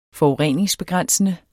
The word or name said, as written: forurening